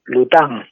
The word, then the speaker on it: Lutin: Ahí estuve viendo que, que, veo que te lo han corregido, sí, porque yo no lo conocía las, cosas de Rabier, que es Tantán Lután, Lutin se escribe, y tiene razón la corrección, Lután en, francés es eh, duende y estuve viendo los dibujitos y es cierto que aparte del nombre tiene cierta...